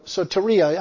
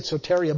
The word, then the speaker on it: soteria: Soteria actually is it pronounced. So they call it, say, a lot of people say it soteria, but it's actually soteria.